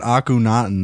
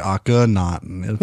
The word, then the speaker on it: Akhenaten: I was pronouncing it Akhenaten that whole episode, and it's actually Akhenaten.